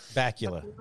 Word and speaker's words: Bakula: Bakula.